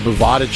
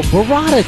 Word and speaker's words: burrata: You never heard of Bovada cheese? Is it Bovada? – No, burrata cheese. – It's Bovada. – No, it's burrata cheese.